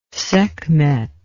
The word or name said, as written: sekhmet